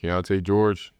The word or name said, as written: Keyonte George